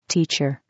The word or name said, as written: teacher